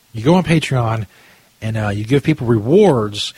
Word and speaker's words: Patreon: There's a website called Patreon. I guess is how it's pronounced. P-A-T-R-E-O-N.